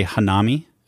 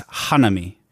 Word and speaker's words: hanami: So we would look at that word and say Hanami. – Okay. – But I believe in Japanese, it's Hanami.